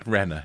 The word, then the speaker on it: Renner: Renner, yeah.